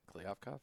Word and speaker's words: Kliavkov: Did I say that right? Kliavkov? – Yeah, it sounded really good.